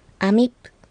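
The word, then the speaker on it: amip: アミップ、ここは最後のプーは小さいピーの発音になりますので、口を必ず閉じた発音をしてください。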